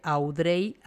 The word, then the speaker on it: Audrey: Y, me puedes encontrar a en todas las redes sociales con, mi nombre, que se escribe Audrey Arronis, se pronuncia Audrey.